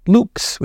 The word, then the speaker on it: Lux: Actually, in Latin, the word would be pronounced Lux, which means light.